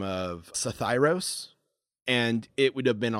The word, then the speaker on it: Psithyros: Sathyros. It's spelled P-S-I-T-H-Y-R-O-S.